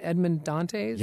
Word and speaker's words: Edmond Dantes: Am I saying this right? Edmond Dantes? – Yes, absolutely.